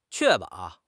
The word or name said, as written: 确保